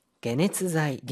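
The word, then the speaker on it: 게네츠자이 (解熱剤): 게네츠자이. – 게네츠자이. 이때는요. 이 해를 게라고 읽어줍니다. 뭐 게로 읽히는 예가 그리 많지는 않지만 그래도 게네츠자이.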